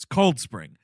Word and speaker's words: Cold Spring: The accentuation is on the word cold, not the word spring. It's Cold Spring. – Yeah. – Everyone in this movie is Cold Spring. Hey, we're going to Cold Spring.